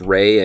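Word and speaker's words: Rea: I actually always thought it was Rhea, but I think I heard recently it pronounced Ray, and I think that might actually be how it is.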